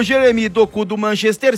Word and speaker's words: Doku: Duas modificações do Rudi Garcia, as entradas de De Bruyne no comando ali na articulação das jogadas, e o Jeremy, Doku, é isso mesmo, isso, no comando de ataque, vai, não...